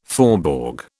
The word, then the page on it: faubourg: British English